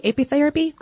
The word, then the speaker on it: apitherapy: There's even a name for this, ape therapy. Did I say that right? Yeah? Apiary? Apitherapy? Yeah, totally.